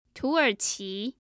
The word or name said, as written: tuerqi